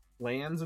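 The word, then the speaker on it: LAN: LANs and WANs, that's how we pronounce it, not lanes and wanes, but LANs and WANs, local area networks, wide area networks.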